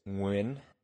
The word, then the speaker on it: Nguyen: It's a phoneme that the English language just doesn't have, which is a subtle NG roll with the back of the tongue. I've tried to teach people how to say it. It's Nguyen,